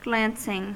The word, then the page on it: glancing: American English